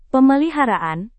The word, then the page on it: pemeliharaan: Indonesian